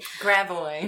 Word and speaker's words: Gravois: So just things that are not pronounced correctly. Gravois. Gravois, yeah. – Instead of Gravois.